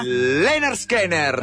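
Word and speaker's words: Lynyrd Skynyrd: Leonard Skinner. – Ah, che pronuncia, come mi piace quando lo pronuncia.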